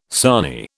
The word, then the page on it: sarnie: British English